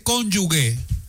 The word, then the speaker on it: cónyuge: El presidente dice cónyuge. No, presidente, no se dice así. Se dice cónyuge. Cónyuge. Porque es G y E. Cónyuge. Repita conmigo, presidente. Con Yu G.